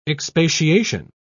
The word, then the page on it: expatiation: British English